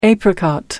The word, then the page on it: apricot: American English